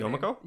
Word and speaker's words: Yomiko: I think, in recalling the anime series, we follow the Paper Sisters, and they have a similar kind of power to Yokimo. I can never pronounce her Yomiko? – Name. Yomiko, sorry.